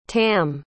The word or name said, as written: tamm